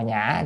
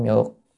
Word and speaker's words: nhã: Chữ nhã này cũng là chữ nhược nha, mà ở đây phải đọc là nhã chứ không âm, không đọc âm nhược.